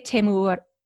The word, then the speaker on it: Temuera: How do you say it? – I would say Temuera.